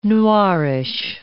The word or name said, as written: noirish